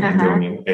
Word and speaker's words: Andonian: So it's spelled just like my name, R-A-F-F-I, which is my first name, Rafi, last name, Andonian, A-N-D-O-N-I-A-N.